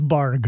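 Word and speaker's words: Barq's: I don't know if it's Barg or Bark. I don't even know how you say it. – Yeah, it's Barg.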